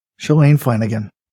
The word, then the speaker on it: Shalane Flanagan: Shalane Flanagan. – Not only does he nail it, he gets the pronunciation of her name right as well. That's correct.